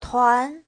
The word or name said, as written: tuan2